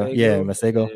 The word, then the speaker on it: Masego: That was the Masego, how do you say Masego? Is that how you say his name? – Yeah, Masego.